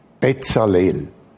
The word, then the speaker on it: Bezalel: Bezalel. B, drei Silben im Hebräischen, B, Zahl L.